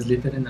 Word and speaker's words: Slytherin: La casa de Slytherin, así es.